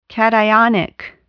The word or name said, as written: cationic